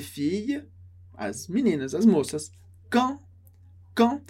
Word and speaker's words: quand: E aqui muito legal a pronúncia de quand. Quand a gente pronuncia sem o D, esse som nasal do an. An. Som que sai de uma só vez. An. An. Quand.